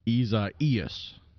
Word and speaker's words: Isaias: And the prescribed pronunciation is Isaias.